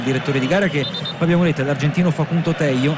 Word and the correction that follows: Tello: anzi Tejo, considerato che in Sud America la doppia L non si legge Ye ma si legge She, e dunque Tejo va a decretare il calcio di punizione all'altezza della linea mediana in favore della Francia.